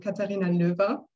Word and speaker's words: Katharina Lower: It is a tricky one. Like really German pronounced, it would sound Katharina Löwe.